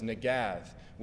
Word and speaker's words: negath: Well, the Hebrew verb for to tell is negath, which rhymes with Gath, the city.